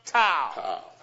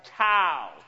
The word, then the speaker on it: tau: Now the T is pronounced tau. Do you see that? Or tau, tau.